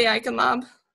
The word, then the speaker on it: Eichenlaub: Ellie Eichenlaub.